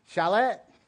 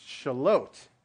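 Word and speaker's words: Charlotte: I'm like, she's like, they're like, is it Shalot? I'm like, no, it's Shalot.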